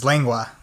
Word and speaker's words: lengua: What is the correct pronunciation of lingua? – The correct pronunciation is lengua, which is essentially means beef tongue.